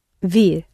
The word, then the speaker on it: vir: Now, it's related to the Latin venire, but again, that N in the middle has dropped out. – Vir.